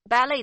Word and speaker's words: Ballet: Ballet 的拼法是 B A L L E。 T，请注意这是一个来自法文的外来词，结尾的 T 不发音。